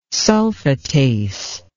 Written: sulfatase

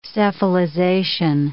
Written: cephalization

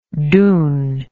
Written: doon

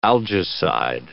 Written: algicide

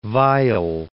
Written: Weill

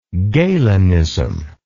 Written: galenist

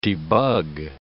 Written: debug

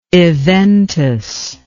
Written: eventus

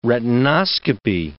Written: retinoscopy

